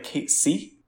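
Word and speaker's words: Cait Sith: Yes, I've heard that Kate, that it's in the next game when he comes out, they're probably gonna say it like Kate C, Cat C, because that's how they say it in Japan. Similar how they say Mako, even though I still Mako to me. So I'll be curious how they say it. It's always Kate Seth to me, but I'll be curious what they say in the next game.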